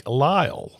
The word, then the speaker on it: Lyle: It is the correct pronunciation is Coach Mike Lyle. No S.